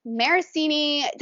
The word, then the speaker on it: Maracine: And today we have on the podcast with us Lisa Maricini.